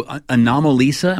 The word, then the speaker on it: Anomalisa: All right, number two, Anomalisa.